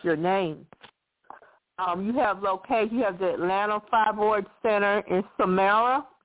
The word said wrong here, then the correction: Smyrna: Did I pronounce it right? The Samara location? – Georgia. We're in West Atlanta in Smyrna.